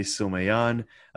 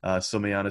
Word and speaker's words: Someillan: My name is AJ, as previously mentioned, and my Twitter is at AJ Someyan. Someyan is spelled S-O-M-E-I-L-L-A-N.